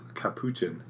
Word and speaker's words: capuchin: It's all about the chin. – Capuchin. Yeah. No, it's about the shin. Capuchin.